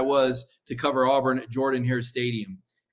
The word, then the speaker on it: Jordan-Hare: And one day, finally, the SID pulled me aside and said, look, if you're going to cover Auburn, you got to say the stadium name right. It's Jordan-Hare. I said, but it's spelled with an O. Trust me, Jordan-Hare for Shug Jordan, Mike.